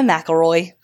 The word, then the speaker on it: McElroy: McElroy.